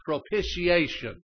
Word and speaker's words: propitiation: And He sent His Son to be, and it's pronounced propitiation. That's just the way it's pronounced. I had to be taught.